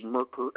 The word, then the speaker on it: Merker: Yes, my name is Richard Merker, M-E-R-K-E-R.